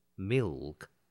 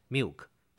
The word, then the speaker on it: milk: 所以一开始我们就介绍这些名称， Pearl Milk Tea 就是珍珠奶茶， Pearl 就是珍珠， Milk 就是奶，这两个字很多人经常发不好，珍珠是必须要卷舌的 Pearl，而牛奶要念成 Milk，很多人的 L 都发不清楚，就念成 Milk，这样是不对的，要念成 milk。